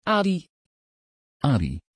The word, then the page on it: adi: Dutch